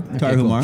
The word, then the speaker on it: Tarahumara: And so they went down to the Copper Canyon in Mexico and ran with I never pronounce right, but the Tarahumara. – Yeah, that's good. Tarahumara.